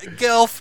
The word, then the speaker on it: Gelf: I appreciate the production design, but it had Gelf, which sounds like elf, which rhymes with I hate it.